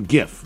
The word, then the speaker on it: GIF: According to Steve Willite himself, the best way to pronounce the word is with a soft G. – So GIF.